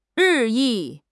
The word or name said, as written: 日益